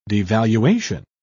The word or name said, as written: devaluation